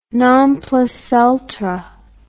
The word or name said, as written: non plus ultra